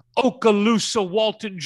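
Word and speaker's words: Okaloosa-Walton: He played at the number one ranked Okaloosa Walton Junior College in Florida.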